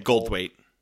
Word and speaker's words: Goldthwait: Bobcat Goldthwait.